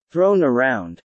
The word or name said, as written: thrown around